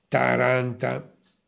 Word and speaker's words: Taranta: in una località che si chiama appunto Taranta. Indichiamo soltanto questa prima citazione, l'accento, per fornire la corretta pronuncia, con annessa e omonima cascina.